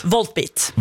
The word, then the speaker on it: Volbeat: og jeg fikk oppleve Volbeat, det riktig uttalt? – Volbeat.